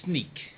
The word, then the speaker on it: Sneek: You're in a place called Snake, is that right? – Yeah, that's right. It's pronounced different than you say it, because it's actually a Dutch name. So it's S-N-E-E-K. – S-N-E-E-K, Sneak. – Sneak.